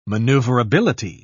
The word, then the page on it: manoeuvrability: British English